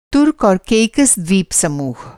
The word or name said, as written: तुर्क एंड केकोस द्वीप समूह